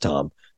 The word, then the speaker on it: Thom: I thought it was Thom, T H O M, but it's pronounced Tom.